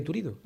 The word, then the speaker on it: Turiddu: Lola y Tirutdu se aman. Y yo lloro, lloro. A ver, ¿cómo se llama? Yo no lo pronuncio bien. – Lola y Turiddu, sí, está bien.